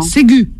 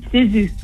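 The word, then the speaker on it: CESU: Cégu. – Cézu.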